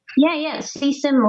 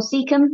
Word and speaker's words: cecum: How do you say that word? – Caecum or cecum. Yep, that's correct. It's really interesting.